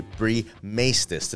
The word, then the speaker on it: Bree Maestas: But we have a very special guest host today, Bree Maestas. Did I say that right? – You did say it right.